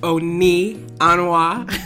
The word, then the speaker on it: Nneka: My name is Nneka, pronounced Oni Anwa.